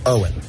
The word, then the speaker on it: Eoghan: No, just Owen. Yes, just plain old Owen. It's so easy.